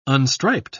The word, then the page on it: unstriped: British English